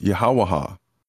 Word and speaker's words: Yahweh: So the number four is the creator of this world is the author of the Bible, YHWH, pronounced Yahweh.